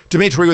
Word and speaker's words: Dimitri: Dimitri with three I's, D-I-M-I-T-R-I. Last name Vassilaris rhymes with Assilaris, but you add a V to it.